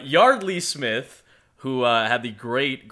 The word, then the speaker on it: Yeardley: We wanted to make sure we did say it is Yardley Smith, despite it being spelled Y-E-A-R-D-L-E-Y. Yes. It is pronounced Yardley, like backyard.